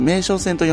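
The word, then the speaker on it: 名松線: 名古屋の名に、えー、松竹梅の松、松ですね、と書いて名称線と読むんですが、この路線の真ん中のあたりに位置します。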